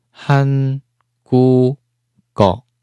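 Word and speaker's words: han gu go (한국어): 它这个收音呢是最下面的，收音在下面的，k k 做收音，所以呢符合了音变条件，最终连音后变什么？读音是 han gu go。第三个字 o 变成了 g o go 是吧？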